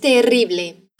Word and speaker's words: terrible: Dime que eso es terrible. Eso es terrible. Atención a la pronunciación de la doble R. Terrible. Eso es terrible.